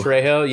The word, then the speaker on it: Trejo: Trejo, yeah, Danny Trejo.